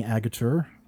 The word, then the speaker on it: Agutter: Before we get into this, we have not talked about Jenny Agutter. I believe that is how I looked it up. That's how you pronounce her name.